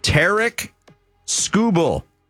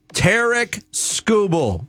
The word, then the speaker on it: Tarek Skubal: It's Tarek Skubal. Not Tariq. Not Tarek. Not Skubal. Not Skrubal. – Yeah. – Tarek Skubal. Four syllables.